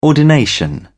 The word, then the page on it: ordination: British English